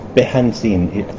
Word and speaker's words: Behanzin: I'm here with er Eric Behanzin.